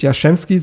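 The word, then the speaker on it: Jastrzembski: Du hast jetzt äh, mit Jaschemski, so wird er übrigens ausgesprochen, äh, ausgesprochen äh, Dennis Jaschemski, der hat einen Profivertrag bekommen mit, glaube ich, erst 17, der ist ein 2000er-Jahrgang.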